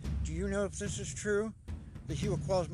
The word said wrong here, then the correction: Huichol: mean H-U-I-C-H-O-L-S. – That's not the way you say it. – Oh, how do you pronounce it? – Huichol.